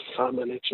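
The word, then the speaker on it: Šamanić: Yes, it's, I think Samanich is the way the team told us to pronounce.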